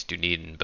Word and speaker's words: Dunedin: I think it's Dunedin, but I'm always tempted to use the Lord of the Rings pronunciation there.